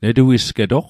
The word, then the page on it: netuisgetoq: Mi'gmaq